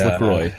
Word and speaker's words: LaCroix: Their website says it rhymes with enjoy. It's pronounced LaCroix.